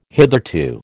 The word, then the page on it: hitherto: American English